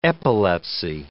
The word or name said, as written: epilepsy